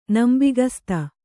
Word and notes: ನಂಬಿಗಸ್ತ: nambigasta

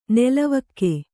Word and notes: ನೆಲವಕ್ಕೆ: Kannada

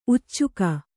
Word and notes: ಉಚ್ಚುಕ: uccuka

